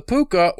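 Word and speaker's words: pooka: Uh, cause in Ireland, in uh, Gaelic, all C's are all K's pronounced, so it would be, still be Puka.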